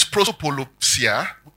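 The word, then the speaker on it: prosopolepsia: Now, the Greek word there for respect of persons is prosopolopsia. Okay, I'll spell that. P-R-O-P-R-O-S-O. P-O-L-E-P-S-I-A. P-R-O, pro, S-O-P, soap, O-L-E, ole, P-S-I, psi, and A. P-R-O, S-O-P, P-R-O, S-O-P, O-L-E-P, S-I-A.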